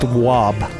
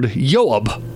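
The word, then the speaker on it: Juab: It's pronounced WOB.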